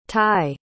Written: tye

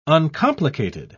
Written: uncomplicated

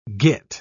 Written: git

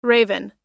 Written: raven

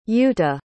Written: yuta

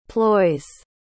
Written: ploys

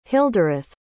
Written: hildireth

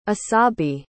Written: Asabi

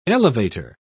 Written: elevator

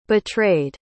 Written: betrayed

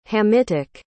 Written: hamitic

